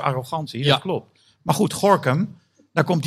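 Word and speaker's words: Gorinchem: Ja, dat hoor je dus uitspreken als Gorkum.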